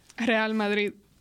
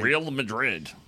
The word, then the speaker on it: Real Madrid: That's what I always heard is Real Madrid. That's not what you said. – Real Madrid. – She said it right.